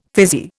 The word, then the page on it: fizzy: Indian